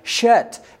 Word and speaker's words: Shet: Remember last week I told you in Hebrew we pronounce that Shet, S-H-E-T. So Seth, the S sound in Hebrew can either be a S sound or it can be a SH sound, depending on where a tiny little dot above the Hebrew letter is.